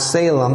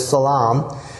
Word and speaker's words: Salem: Well, Melchizedek came and visited Abraham from the city of, which people pronounce Salem, but it's Salaam.